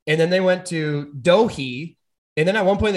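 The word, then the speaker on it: Doege: My biggest annoyance is, so Jared Doge, I think it's D-O-E-G-E.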